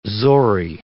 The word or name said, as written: zori